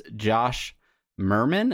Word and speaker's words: Merman: We have a very special guest with us today from Zen Monkey Studios, Josh Merman. Did I pronounce that right, Josh? – You pronounced it correctly.